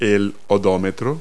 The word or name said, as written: odometer